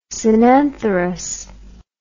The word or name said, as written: synantherous